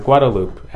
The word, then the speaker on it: Guadalupe: Yeah, just right off of Guadalupe. And I'm not pronouncing it wrong. That's how people say it here.